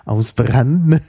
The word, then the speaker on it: Hausbrandt: Hausbrand, ja. Also der wird natürlich auf Italienisch ausbranden. – Okay. ausbrande ausgesprochen. Oder ausbrannte.